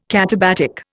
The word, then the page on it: katabatic: Indian